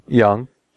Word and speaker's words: Jung: But what's interesting is, one guy, Young Hoon, J-U-N-G is how you say his first name. – Young.